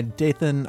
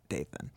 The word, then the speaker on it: Dathan: D and, yeah, it's like Nathan, but Dathan. Unless it isn't pronounced that way.